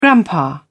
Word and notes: grandpa: British English

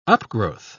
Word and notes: upgrowth: British English

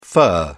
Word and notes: fir: British English

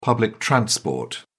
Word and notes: public transport: British English

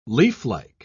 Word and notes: leaflike: British English

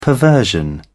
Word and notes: perversion: British English

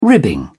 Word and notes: ribbing: British English

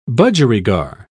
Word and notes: budgerigar: British English

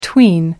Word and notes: tween: American English

